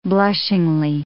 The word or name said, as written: blushingly